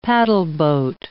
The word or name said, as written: paddleboat